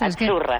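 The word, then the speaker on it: Azzurra: Achurra. – Es que... – Sí, como porque pizza. La Z no es la Z como decimos en catalán tan sonora, no.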